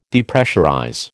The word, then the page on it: Depressurize: American English